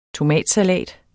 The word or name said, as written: TOMATSALAT